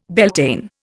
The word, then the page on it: Beltane: Indian